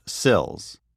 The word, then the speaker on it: SLLS: We call this simple action SLLS, pronounced SILS.